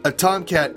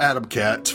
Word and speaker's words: Atom Cat: But A Tomcat is, or Atom Cat, rather. You could say Atom Cat, A Tomcat. I think Tezuka did it that way, so you could pronounce it either way.